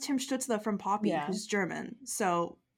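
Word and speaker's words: Stützle: Like if we just like do a German accent for Tim Stutzle, I don't know how to say his name in a German accent, but like I think that's the move going on.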